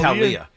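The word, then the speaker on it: Taulia: I, I, how do you say his name again? Talia. Talia. Talia, Talia. Tagovailoa.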